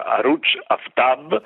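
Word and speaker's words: Arooj Aftab: La recensione riguarda una giovane musicista il cui nome si pronuncia Aruj Aftab, è scritto in realtà AR2O e una J e poi Aftab.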